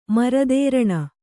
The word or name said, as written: ಮರದೇರಣ